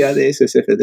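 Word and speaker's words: AdS/CFT: no sé si se pronuncia de una manera concreta, pero es ADS partido CFT. ¿Cómo lo pronuncias? Porque siempre lo he visto escrito, te lo prometo. – Sí, ADS CFT está bien.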